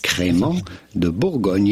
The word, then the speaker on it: Crémant de Bourgogne: Crémant de Bourgogne.